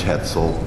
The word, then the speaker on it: Tetzel: And to pay him back, they hired a fund developer named Tetzel, rhymes with pretzel.